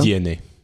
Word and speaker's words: DNA: si je me trompe pas on prononce comme ça DNA DNA,